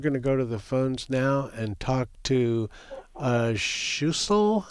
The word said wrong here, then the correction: Schussler: Am I mispronouncing your name. – No, you are almost correct, Schussler. – Oh, Schussler, okay.